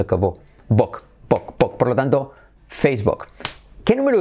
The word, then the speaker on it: Facebook: La número uno, number one, la más conocida, la que más usuarios tiene, que es, como decimos en español, Facebook, pero ¿cómo se dice realmente Facebook? Tiene la letra semiclave U como O, precisamente porque es muy recurrente el decir book y queremos acabar con eso para salvar el inglés, que todo el mundo diga book.